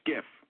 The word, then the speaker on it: SCIF: Sensitive Compartmented Information Facility, pronounced SCIF.